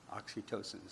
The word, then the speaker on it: oxytocin: Oxytocin.